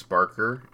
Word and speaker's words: Sparkr: It's Sparkr. It's spelled S-P-A-R-K-R.